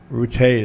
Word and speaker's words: Rutte: I think it's Rutte is the way you pronounce his last name, the head of NATO.